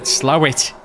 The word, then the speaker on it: Slaithwaite: It's like in Yorkshire, there's a place called Slaithwaite, but you call it Slawit. – Oh, how do? – It's, do you come from Slawit? – Slawit, no, Slaithwaite.